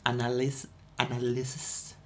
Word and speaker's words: analysis: I have a specific analysis. Is that how you pronounce that word? – Yes. – Analysis. Yes, stress on the second one.